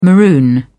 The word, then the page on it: maroon: British English